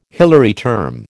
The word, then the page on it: Hilary term: American English